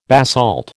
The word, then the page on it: basalt: American English